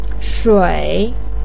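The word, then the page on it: shui: tone 3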